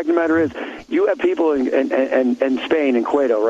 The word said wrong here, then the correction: Ceuta: Where you have the Ceuta. It's supposed to be pronounced Ceuta. I read that.